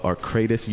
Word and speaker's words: Arcadis: Today we are talking to Michael Wooten, VP of Arcadis U.S.